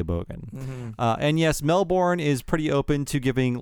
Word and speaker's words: Melbourne: And Bradley Winton says, "Gonna be that Aussie, but it's pronounced Melbourne and not Melbourne. And if you really want to be Aussie about it, it's Melbourne."